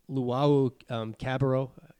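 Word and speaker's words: Luwawu-Cabarrot: He, he had one on Luwawu um, Cabarrot. Did I did I butcher that? Perfect.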